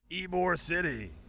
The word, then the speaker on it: Ybor City: It's Ybor City. – Okay. – Yeah, I'm not a fucking moron. I've been here. – Is that how you say it? – Yes. Ybor City.